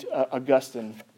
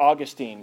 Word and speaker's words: Augustine: There's this quote from St. Augustine, or Augustine, if you prefer that pronunciation.